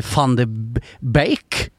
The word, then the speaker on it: van de Beek: van de Beek. – Helt korrekt uttalt.